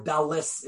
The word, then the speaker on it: Dalet: Which, if you remember, a million years ago when we studied it, it's not D-A-L-E-D. That is a mispronunciation of the letter. It's D-A-L-E-T. Dalet is really the correct. Or Dalis, if you really want to be Ashkenazic.